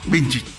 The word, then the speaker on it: Slavko Vinčić: A ver si lo digo bien. Slavko Vinčić. – Vinčić. – Así es.